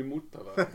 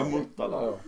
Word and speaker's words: Motala: han, är upp, han är upptagen i Muttala Ja. Motala.